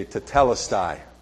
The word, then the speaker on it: tetelestai: And I've been practicing all week, and this is how you pronounce it. My family's like, shut up. Everybody repeat after me, tetelestai. Tetelestai. That's how you say, that's one word in the Greek that means it is finished.